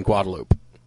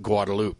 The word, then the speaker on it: Guadalupe: And I actually got an email about that because half the stuff we do is on Guadalupe, which technically should be pronounced Guadalupe. But if you're an Austin native, you know that it's correct for Austinites to pronounce it Guadalupe.